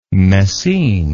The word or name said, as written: messines